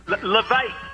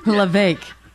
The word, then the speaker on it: Levesque: Levesque. It rhymes with heartache. – Levesque.